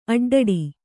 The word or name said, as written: ಅಡ್ಡಡಿ